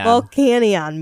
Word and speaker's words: Volcanion: I keep saying it wrong. What I saw of the Volcaronin movie. Volcanion movie.